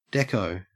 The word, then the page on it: dekko: Australian English